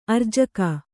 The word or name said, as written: ಆರ್ಜಿಕೆ